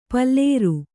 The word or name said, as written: ಪುಲ್ಲೋರಿ